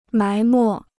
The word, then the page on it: 埋没: mái mò